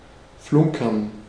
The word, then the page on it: flunkern: /ˈflʊŋkɐn/